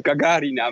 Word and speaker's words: Gagarina: Heel goed, Gagarina met uh, op de tweede lettergreep.